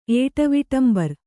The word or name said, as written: ಏಟವಿಟಂಬರ್